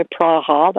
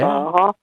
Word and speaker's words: Praha: But the other one was when I went to Czech Republic to Prague, you know, Praha. They like to pronounce it, yeah. Praha.